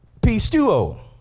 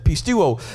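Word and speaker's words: pistuo (πιστεύω): So this Greek word is pistuo. That's how you pronounce that, pistuo.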